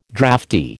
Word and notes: draughty: American English

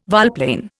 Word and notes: volplane: Indian

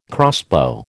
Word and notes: crossbow: American English

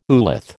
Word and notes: oolith: American English

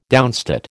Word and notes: downstate: American English

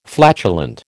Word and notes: flatulent: American English

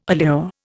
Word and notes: allure: Indian

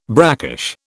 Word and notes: brackish: British English

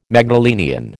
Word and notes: Magdalenian: American English

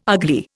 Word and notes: ugly: Indian

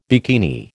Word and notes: bikini: American English

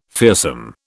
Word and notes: fearsome: British English

